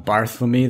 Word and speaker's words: Barthelme: This is called The Balloon by Donald Barthelme. That pronunciation is now under review, but we think it's correct.